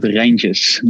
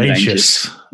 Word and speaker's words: Reintjes: You call it Reintjes. Martijn Reintjes. – Reintjes. – Proper Dutch.